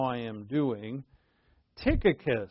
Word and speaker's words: Tychicus: But anyway, the accent is on the last syllable, so it's Tychicus. It just doesn't sound right in English, so we'll call him Tychicus.